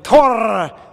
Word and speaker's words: Tore: Tore, is it Tor? It's Tor, right? Tor Johansson. – Depends on what part. – English. Tor. – English speakers say he. Everyone says Tor if they're English speakers.